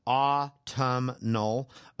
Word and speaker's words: autumnal: Autumnal. It is. And then Kristen, thank you for the tutorial on null at the end of autumn. That phonetically breaks it down for me perfectly.